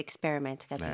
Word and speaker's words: experiment: 그리고 사실 첫 글자 E가 그렇게 강하지 않아요. – 오케이. – E보다는 E. Experiment.